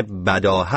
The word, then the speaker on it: بداهه: صحیح نام تلفظ این کلمه بداهه است و بداهه نوازی، گرچه ممکنه که امروز به نوع دیگری تلفظ بشه.